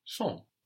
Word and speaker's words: sont: They are, they are, is il or elle, sont. That's spelled S-O-N-T, sont. S-O-N-T.